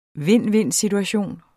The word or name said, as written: vind-vind-situation